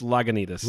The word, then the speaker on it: Lagunitas: It's Lagunitas.